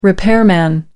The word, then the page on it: repairman: British English